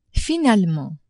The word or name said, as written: finalement